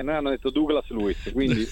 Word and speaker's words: Douglas Luiz: Mi diceva: adesso alla prima pausa ti mando un vocale e ti dico come lo devi pronunciare. E mi ha detto: devi pronunciarlo Douglas Luiz.